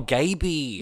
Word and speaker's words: Gayby: G-A-Y-B-Y. – Oh, Gaby. – Yeah, okay. – Like gay baby.